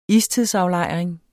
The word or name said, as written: istidsaflejring